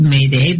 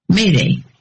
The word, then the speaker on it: m'aidez: It says this comes from the French word, I'll spell it, it's pronounced Mayday, but it's M'AIDEZ, which means help me. And it's pronounced Mayday.